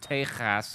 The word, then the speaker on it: Tejas: And to be fair, it is pronounced "Tejas."